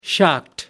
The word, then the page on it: shocked: British English